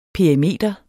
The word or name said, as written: PERIMETER